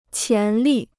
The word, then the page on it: 潜力: qián lì